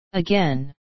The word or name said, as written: again